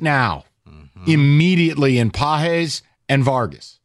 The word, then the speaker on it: Pages: I know it's spelled Pages, but it's Páez.